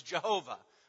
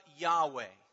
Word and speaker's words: Yahweh: Those of us who have been in the church for years and years may remember when we were younger, the most common pronunciation was Jehovah. We have songs with the word Jehovah in it. Scholars today are leaning more towards a pronunciation of Yahweh.